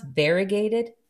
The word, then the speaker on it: variegated: Although I am a big fan of the, is it pronounced variegated? Mm-hmm. Is that how you pronounce it? – That's how I pronounce it. So that's how it's pronounced.